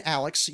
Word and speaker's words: Alexx: I have been Alex.